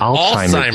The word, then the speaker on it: Alzheimer's: How do you not hear it? – Alzheimer's disease. – It's even spelt phonetically with a T. – Alzheimer's disease. – Because the Z is pronounced that way.